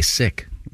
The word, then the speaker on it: Sikh: The New York-based Sikh Coalition says the correct pronunciation is S-I-K, Sikh.